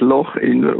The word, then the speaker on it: Lochinver: Ja, ja, auf Schottisch spricht man das Loch Inver aus. Der Engländer sagt Loch Inver, weil er es nicht sagen kann.